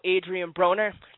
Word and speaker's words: Adrian Broner: Yeah, speaking of making weight, we have a caller on the line right now, Fabian, who wants to talk about Adrian Broner. Did I pronounce that right, Fabian? – Yep, you got it correct.